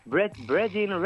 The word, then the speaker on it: Brethren: Brethren Records, dejarme que lo pronuncie bien. Brethren Records. – Yeah, Brethren, que viene de Brethren, como de hermandad.